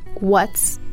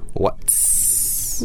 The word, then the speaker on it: what's: So when you do pronounce what's, make sure, it's very important to make sure you pronounce the T. – Mm. – By moving your tongue to the roof of your mouth and then pronounce the S.